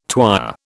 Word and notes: twyer: British English

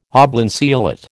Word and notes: oblanceolate: American English

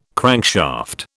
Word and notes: crankshaft: British English

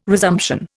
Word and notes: resumption: Indian

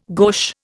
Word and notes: gauche: Indian

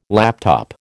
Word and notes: laptop: American English

